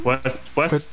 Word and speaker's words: Wet'suwet'en: Wet'suwet'en. – Wet'suwet'en.